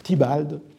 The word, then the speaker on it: Theobald: Sauf qu'en 1727, Lewis Theobald, on prononce à la suite de Pope, avec qui les conflits ont été très grands, Thibald, mais cela s'écrit Theobald, T-H-E-O-B-A-L-D, qui était un des éditeurs de Shakespeare,